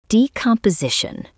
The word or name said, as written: decomposition